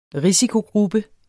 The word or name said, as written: risikogruppe